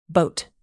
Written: boat

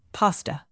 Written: pasta

